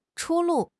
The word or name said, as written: 出路